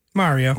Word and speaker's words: Mario: It's not Mary with an O. – Yeah, Mario. That's a good point. Yeah, I never really thought about that. How does he say it in the movie? – Mario.